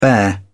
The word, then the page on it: bear: British English